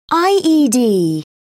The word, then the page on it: ied: British English